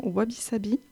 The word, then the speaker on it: Wabi Sabi: Dites-moi si je le prononce bien, Wabi Sabi, aux éditions La Martinière. – Tout à fait.